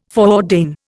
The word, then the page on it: foreordain: Indian